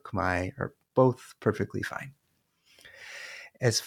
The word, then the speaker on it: Khmer: It's as pronounced in Cambodia in most dialects. It's pronounced Khmai, and that pronunciation is often favored in Cambodian diaspora communities. In other dialects, particularly dialects spoken in of Cambodian spoken in Northeast Thailand, the pronunciation may be closer to Khmer.